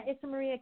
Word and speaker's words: Isamary: I hope I'm pronouncing it right, Lupi? Issa Marie Aquino? – Yeah, Issa Marie Aquino.